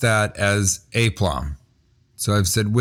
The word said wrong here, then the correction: aplomb: And he goes, how do you pronounce this word? And he pointed to the word aplomb. Mm-hmm. And I said, aplomb. And he goes, seriously? And I was like, yeah. And he goes, it's aplomb.